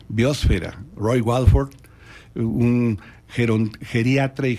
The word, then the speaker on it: biosfera: La única que tiene la pronunciación que nosotros usamos como mexicanos es atmósfera. Lo demás debe ser hidrosfera, litosfera, biosfera.